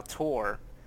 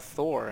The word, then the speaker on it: Thor: It's not Randall Tor, it's Randall Thor. And I'm like, why is it pronounced Thor? Tom, who is also Andorian, is spelled mm-hmm. T-H-O-M, and it's pronounced Tom. Rand is Andorian. It's spelled T-H-O-R and pronounced Thor?